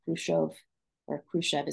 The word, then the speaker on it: Khrushchev: And there are several quotes from different Soviet actors, including, I think, Khrushchev is how we pronounce it in America, himself, that they had to go in and kind of degrade the morals, the sexual morals of the young people.